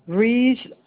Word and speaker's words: Ruiz: The way G-L-O-R-I-A. Yes. – Okay, and your last name? – Riz Silva. Riz, R-U-I-Z. And Silva is S-I-L-V-A. – All right. So I have Gloria, G-L-O-R-I-A, Ruiz, R-U-I-Z, Silva, S-I-L-V-A.